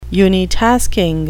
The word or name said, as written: unitasking